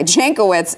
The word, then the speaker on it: Jankowicz: They appointed Nina Jankowicz. Am I saying this correctly? I don't really know. – That seemed right. – Okay. Jankowicz as executive director of the board.